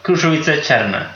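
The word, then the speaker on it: Krušovice Černé: Får jag försöka med uttalet? En krusovitz cerne. Cerne. – Ja, krusovitz cerne.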